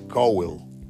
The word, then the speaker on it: Cowell: Esta contratación de un muchacho de 20 años, de nombre Kate Caldwell, otra vez Kate Cowell, lo dije mal al principio, no lleva D anterior a, la W, es Kate Cowell o Cowell.